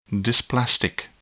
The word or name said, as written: dysplastic